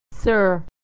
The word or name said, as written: sir